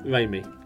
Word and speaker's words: Raimi: Raimi.